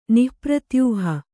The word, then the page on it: ನಿಃಪ್ರತ್ಯೂಹ: nih pratyūha